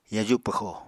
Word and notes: yaju pwxo: Apatani

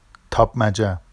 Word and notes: tɑpmaʤa: Vafsi

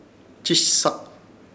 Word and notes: Chishsak: Ishir Chamacoco

